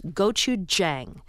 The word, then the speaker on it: gochujang: The one I'm enjoying the most right now, especially when I go out to dinner, is the Korean hot sauce that I believe is pronounced gochujang.